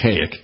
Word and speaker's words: Kazik: K-A-Z-I-K, pronounced Kayak. Silent Z, I think.